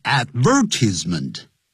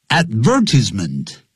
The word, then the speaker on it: advertisement: 如果是英国人还会念成 advertisement， advertisement，那是英国人念法。那美国人是念的 advertisement。那注解呢，很多字典会念成 advertisement，重音在第三音节。